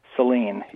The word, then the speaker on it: Saline: Calling from Saline, or I'm not pronouncing it correctly. Is that Michigan? – Yes, it's Michigan. And yeah, it is. Saline. Saline, yes. – Yes, okay, Saline. I got it right.